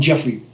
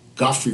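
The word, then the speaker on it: Geoffrey: And his wonderful wife, we all call him Jeffrey Holder. – Right. – Godfrey Holder, that's his real pronunciation, from the Caribbeans.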